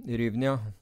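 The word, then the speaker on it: hryvnia: Det begynner på H, og ikke på, på U av en eller annen grunn. Men uh, du kan prøve å uttale det, du. Men det er en uh, eh, eh. Ryvnia. Ryvnia. Det tror jeg er ganske nært.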